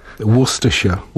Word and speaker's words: Worcestershire: And Hrabstvo is Worcester. – County. – Worcestershire. – Worcestershire. – But it's spelled Worcestershire.